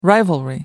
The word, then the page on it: rivalry: American English